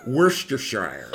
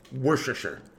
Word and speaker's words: Worcestershire: It's like saying Worcestershire. Like no one said, so you might as well just say it really fast. Worcestershire.